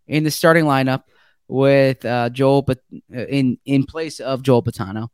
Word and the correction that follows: Betonio: Betonio. – I apologize. I got to get my pronunciations down.